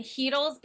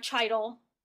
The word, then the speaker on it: Hedl: And Hedl's been good. It's not Chidl. It's Hedl. – I know. I was just wondering, how do you say that name? – It's Hedl. It's Hedl.